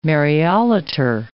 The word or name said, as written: Mariolater